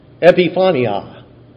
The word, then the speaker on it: epiphania: epiphania.